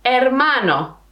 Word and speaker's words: hermano: Hermano. Hermano. Hermana. Hermana. So remember, in Spanish it's very important to know that we don't pronounce the H in Spanish. The H is silent in Spanish.